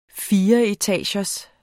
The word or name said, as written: fireetagers